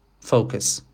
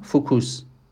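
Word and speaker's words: focus: مثلا من از قبل کلمه مثلا تمرکز فوکس که تلفظ درستش هست رو خودم چشمی دیدم، فوکوس اینو چندین سال من توی ذهنم دارم،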